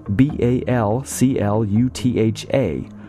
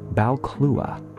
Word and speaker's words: Balclutha: It's spelled B A L C L U T H A, which looks as though it ought to be pronounced Balclutha, but because the name is of Scottish Gaelic origin, that TH just gets left out in the cold. Balclua.